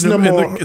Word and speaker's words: Namor: Wait, is it pronounced Namor? Am I saying it wrong? – No, it's Namor. It's Namor. In the movie, it's Namor.